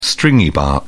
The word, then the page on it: stringybark: British English